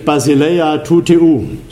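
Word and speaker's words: Basileia tou theou (βασιλεία τοῦ θεοῦ): Basileia tou theou.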